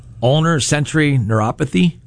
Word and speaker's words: ulnar sensory neuropathy: Unfortunately, now I have ulnar sensory neuropathy. Did I say that right? – Yes.